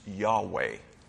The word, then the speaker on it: Yahweh: Rather, the biblical scholars in recent years have said probably the correct pronunciation of YHWH is Yahweh.